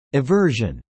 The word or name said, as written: aversion